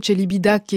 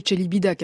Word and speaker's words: Celibidache: que l'on devrait prononcer Celibidache, et comme me le dit cette auditrice, que je salue d'ailleurs, mais que l'on connaît mieux en France, prononcer Celibidache.